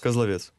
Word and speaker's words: Козловец: Козловец. – Козловец, туда ударение.